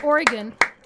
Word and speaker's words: Oregon: Oregon.